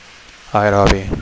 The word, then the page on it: aerobi: Catalan